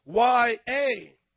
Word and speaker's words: Yahweh: So it's YAHWEH, Yahweh.